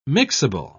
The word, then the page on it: mixable: British English